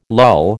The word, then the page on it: lull: American English